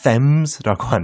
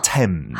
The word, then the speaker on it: Thames: 그 스펠링대로 읽으면 안 돼요. 참고로 T H A M E S. – Uh-huh. Thames라고 안 읽고 어떻게 한다고요? – H가 묵음이라서 Thames.